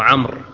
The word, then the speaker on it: عمرو: عند الوقف عمرو وعند الوصل عمرو بن العاص، وهذا خطأ شائع، فالواو تكتب ولا ينطق بها إلا روما عند الوصل.